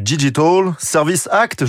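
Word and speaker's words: Digital Services Act: Les géants du net, désormais sous contrôle avec l'entrée en vigueur du Digital Service Act, je le prononce bien, non, ce vendredi Très bien.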